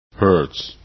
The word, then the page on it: hertz: english